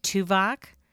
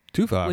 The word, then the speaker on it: Tubac: Which is spelled T-U-B-A-C. – He's on Voyager. He's the Vulcan on Voyager. – No, but it's actually a place, but that's pronounced with like a V instead of a B.